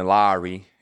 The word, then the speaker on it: Lauri: Yeah, it was super dope speaking to Lowry and getting the proper pronunciation of his name. It could be Kyle Lowry, similar way to say it, but he was more of a fan of Mike Lowry.